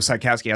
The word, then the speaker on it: Sykowski: So Sykowski, Sykowski, that's a phonetic spelling of the Americanized pronunciation of our last name. But in Polish, my last name's Chakowski.